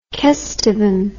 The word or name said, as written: kesteven